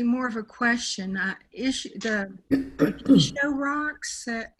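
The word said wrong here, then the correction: Jizō: The Gisho rocks that we were going to paint in May, did that? – Gisho. – What is it? – Gizo, J-I-Z-O.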